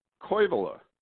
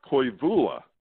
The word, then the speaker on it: Koivula: Chris King said, hey, you know, it's Otto Koivula, not Koivula.